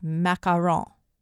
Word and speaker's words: macarons: The pretty little jewel-colored French cookies known as macarons. And yes, that is how you say it.